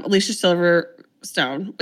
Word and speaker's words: Alicia Silverstone: We open on Laura, who is played by um, Alicia Silverstone.